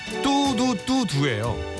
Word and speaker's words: 뚜두뚜두: 블랙핑크의 뚜루뚜루 기억납니다. 예, 사실은 뚜두뚜두예요. 뚜두뚜두. 네, 발음 안 되는 우리 아저씨들은 뚜루뚜루라고 계속 소개를 했었던 저도 계속 뚜루뚜루라고 얘기해서 수현이한테 많이 혼났던 오빠 뚜두뚜두야 그랬던 노래.